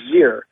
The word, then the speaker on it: xyr: Well, it's spelled X-E-X-E-M and X-Y-R, and it's pronounced Z-Zem and Zir.